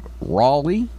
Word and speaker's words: Roley: A guy named Raleigh. Is that how you say it? – Yep. – Raleigh? – Pete Raleigh. – R-O-L-E-Y.